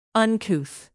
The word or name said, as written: uncouth